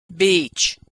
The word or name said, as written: beach